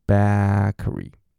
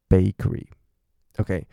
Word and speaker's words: bakery: 所以 bakery 很多人念成 bakery，所以你让你下巴顶好，然后顶回去 bakery，OK，所以这就是几个大家很容易会出现的一些发音的精准度的问题。